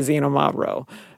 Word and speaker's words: Xinomavro: And so sometimes how Xinomavro is actually spelled, it's X, it's not Z, it's Xinomavro. But sometimes instead of XI, it will be XY. So, and then how you pronounce it. – Because they can't agree. – And then they can spell it however they want. – Yes, and it's pronounced with sounding like it's a Z, and it's actually an X.